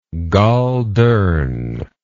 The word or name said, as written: goldurn